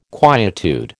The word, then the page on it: quietude: American English